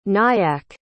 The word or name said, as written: nyack